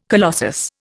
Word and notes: colossus: Indian

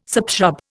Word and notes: subshrub: Indian

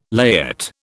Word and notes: layette: British English

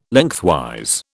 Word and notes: Lengthwise: British English

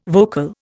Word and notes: vocal: Indian

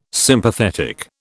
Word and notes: Sympathetic: British English

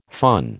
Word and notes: fun: American English

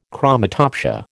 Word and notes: chromatopsia: American English

